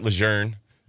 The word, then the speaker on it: Lejeune: Shout out Camp Lejeune. That's the proper pronunciation.